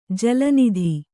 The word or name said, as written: ಜಲನದಿ